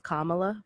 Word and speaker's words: Kamala: With that, do you think Cory and or Kamala is going to end up being POTUS? – I think her name's pronounced Kamala, but it's okay.